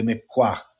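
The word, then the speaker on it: Mi'kmaq: it's difficult to pronounce, but it's M-I apostrophe K-M-A-Q, and that would be the Miquaq people, the Indian race.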